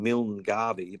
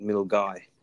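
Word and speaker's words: Milngavie: I would read it as saying Milne Garvey, but you're supposed to pronounce it Milgai.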